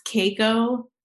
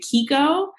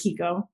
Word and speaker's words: Keiko: So my middle name, and it's usually pronounced Keiko, but my family, I guess being American, they just didn't know how it was actually pronounced. So we've always pronounced it Kiko. And So I just always pronounce it Kiko now.